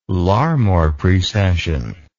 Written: Larmor precession

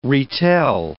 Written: retell

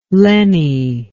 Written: lenny

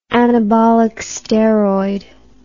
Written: anabolic steroid

